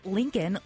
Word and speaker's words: Lincoln: Well, it says Lincoln like the president.